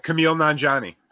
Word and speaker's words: Kumail Nanjiani: Pronounce it correctly for us, because I murdered it. I know. Oh, Kumail Nanjiani. Yes, much better.